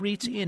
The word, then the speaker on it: REITs: Yeah, was it, okay, that's the REITs, pronounced REITs, and it's, was that the one, the pipeline, which one of those was that? – I'm not sure.